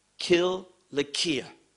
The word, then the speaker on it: Cilicia: Now, I realize many want to pronounce it with an S sound because it's written in C, but in the Greek language, it is Cilicia.